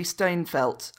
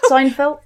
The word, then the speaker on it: Steinfeld: Do you know, it gave me Dawn from Buffy the Vampire Slayer vibes when Hayley Steinfeld, I completely just mispronounced her name. – Steinfeld? Yeah. – That's it. Thank you.